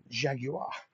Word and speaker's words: Jaguar: I went out and bought a Jaguar. Yeah, three syllables, Jaguar.